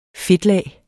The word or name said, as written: fedtlag